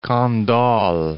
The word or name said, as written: Candolle